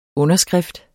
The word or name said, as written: underskrift